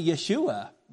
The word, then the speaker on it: Yeshua: And so really the correct way to say that would be Yeshua, which would be, okay, Judea, the Shomron, and Gaza.